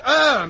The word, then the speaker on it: heure: The word er means... – She. – She, like look at her. No, it doesn't mean she at all. This is spelt H-E-U-R-E, and it means hour.